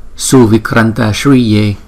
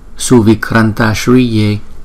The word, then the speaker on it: Suvikranta Shriye: Thiện Du Bộ Công Đức Phật, chúng ta sẽ phát âm là Suvikranta Shriye, Suvikranta Shriye, Suvikranta Shriye.